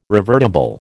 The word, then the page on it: revertible: American English